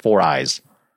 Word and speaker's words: 4iiii: Is it 4Eye or is it 4II? – You know, my understanding is it's supposed to be pronounced 4Eyes.